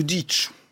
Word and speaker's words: Dietsch: et la musique à Louis Dietsch, ou Dietsch, pour prononcer à l'allemande.